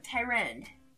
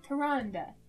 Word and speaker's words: Tyrande: So number one, Sylvanas, Jaina, or Tyrand? Am I saying that right? – Tyrande.